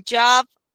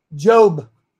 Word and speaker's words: Job: So the reading today will be from Job 34:20 through 23. – Myra, long O, long O, Job. Job, like Joe. Hey, Joe. Job.